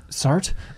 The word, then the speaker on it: Sartre: So you can see where the car you just unlocked compares to, for example, when Jean-Paul Sartre, is that how you pronounce his name? – I think it's Sartre.